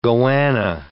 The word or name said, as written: goanna